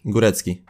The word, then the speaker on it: Górecki: Są też polskie akcenty, bo Hayley Góra, Górecki. Górecki. Dokładnie. Górecki.